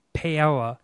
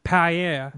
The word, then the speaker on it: paella: 16, putting chorizo and hard-boiled eggs in paella. – Paella. Here, paella. Thank you for cleaning that up.